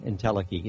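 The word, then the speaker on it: entelechy: And the word IntelliKey, it's E-N-T-E-L-E-C-H-Y, IntelliKey it's pronounced, and it means the complete actualization as opposed to or as compared to potentiality.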